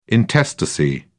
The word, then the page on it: intestacy: British English